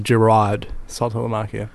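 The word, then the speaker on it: Jarrod Saltalamacchia: I think it's pronounced Jerod Saltalamacchia.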